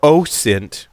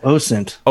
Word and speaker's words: OSINT: It's nice to have you here talking about OSINT. I said that correctly. – There you go. – Time. – OSINT.